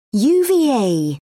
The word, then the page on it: uva: British English